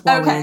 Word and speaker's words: Huawei: There's an extra syllable somewhere in there and I can't hear it. So, but I think for most of us, Huawei is okay.